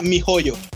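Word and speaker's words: miHoYo: ¿Mioyo? – Mioyo. sí, suena, como, suena como mal, pero no, no son mal pensadas, banda, así se llama. De hecho, se pronuncia mi joyo, mi joyo, mi joyo.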